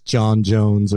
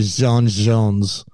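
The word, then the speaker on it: J'onn J'onzz: Like, most of the time it's John Jones, or sometimes you think they're saying Sean Jones or whatever, but they actually Frenchify it. And so, just like, you know, Jean Valjean in, Les Miserables, Jean Valjean, it's actually Jean Jones, right? – Okay.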